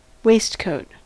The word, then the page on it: waistcoat: American English